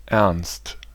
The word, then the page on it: ernst: [ˈɛrnst]; /ɛʁnst/